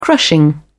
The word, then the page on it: crushing: British English